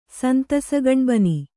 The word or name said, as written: ಸಂತಸಗಣ್ಬನಿ